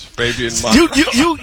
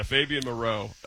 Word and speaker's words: Fabian Moreau: Yeah, Fabian Moreau is the correct way.